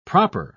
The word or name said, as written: proper